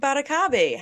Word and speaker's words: Batocabe: I am joined by Greg Batocabe.